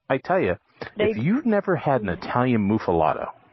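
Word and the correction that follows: muffuletta: am I saying that right? Muffalato? Muffalata? – Muffaletta.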